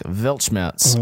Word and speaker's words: Weltschmerz: So now we move on to the final song on this record, the aforementioned title track, Weltschmerz. Mm. What did you think of this one, Dave? – So tell me I said that right. – You did.